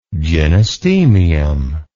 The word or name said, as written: gynostemium